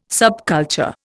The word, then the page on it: subculture: Indian